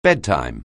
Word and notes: bedtime: anglais

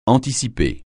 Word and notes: anticiper: francais

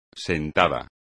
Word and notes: sentada: espagnol

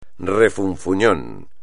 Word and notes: refunfuñón: espagnol

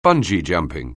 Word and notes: bungee jumping: anglais

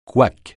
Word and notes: couac: francais

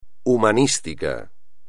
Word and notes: humanístico: espagnol

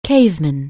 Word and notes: caveman: anglais

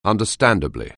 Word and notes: understandably: anglais

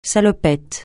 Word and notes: salopette: francais

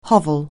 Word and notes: hovel: anglais

